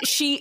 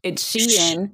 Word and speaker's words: Shein: Is Shein. It is not Sheen.